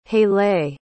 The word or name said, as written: haileigh